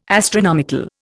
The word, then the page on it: astronomical: Indian